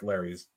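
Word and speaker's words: Larys: Varys and no, it's Varys and Larrys. Like they couldn't. No, it's Larrys. It's Lord Larrys. That's how they pronounce it in the show.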